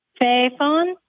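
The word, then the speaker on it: fy faen: Okay, how would you say that? – Fefon. – Hey, say that again. – It's Fefon. It's F-Y space F-A-E-N.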